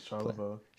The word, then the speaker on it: Charlevoix: So he's got two from the Charlevoix, how do you say it? – Charlevoix. – Charlevoix place and Chattanooga.